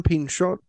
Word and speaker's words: Pinchot: I love that scene on the roller coaster when they meet that, I always forget how to pronounce his name, is it Bronson Pinchot? – Yeah, French. His name is Bronson Pinchot.